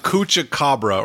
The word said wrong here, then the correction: chupacabra: or chucabra. – Wait, I just want to hear him continue to say it. – It's chupacabra. – That's what I'm saying.